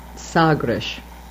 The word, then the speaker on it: Sagres: And speaking of pronunciation, you probably know it, but nevertheless, down in the Algarve where we filmed Henry the Navigator's school out on the promontory, it's in the town of Sagres. S-A-G-R-E-S.